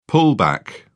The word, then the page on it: pullback: British English